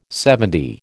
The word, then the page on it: LXX: American English